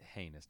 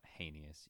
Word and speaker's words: heinous: It's heinous, not heinous, you freak. There's not an extra syllable in there.